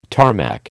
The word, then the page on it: Tarmac: American English